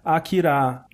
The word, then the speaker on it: Akira: Com licença, o idioma japonês ele é bem silabado e não tem ênfase em nenhuma sílaba como no português. Então você tem que falar Akira.